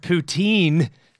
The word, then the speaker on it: poutine: Poutine, pronounced poutine. Stress on the second syllable.